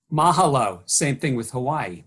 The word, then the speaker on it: mahalo: Mahalo. Okay. Mahalo. Ma- so the accent's on the first syllable. – Uh, mahalo. Mahala. Yeah, on the ma.